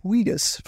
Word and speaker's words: Wheatus: seeing the band Wheatus for the first time. – You say it like Wheaton.